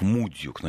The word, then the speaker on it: Мудюк: И меня, кстати, просят, чтобы я поправил ударение остров Мудюк на первый слог.